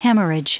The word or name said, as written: hemorrhage